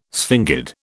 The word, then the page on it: sphingid: British English